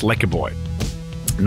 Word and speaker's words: LEKKERBOY: Their new album, which I stupidly, as you'll hear in a second, mispronounced. I'm sorry. It's LEKKERBOY.